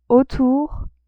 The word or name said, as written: autour